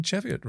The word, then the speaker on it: Cheviot: So the only candidates left were rocks from the Cheviot Hills. And am I pronouncing Cheviot right? Is it Cheviot? – Yes. – Hills.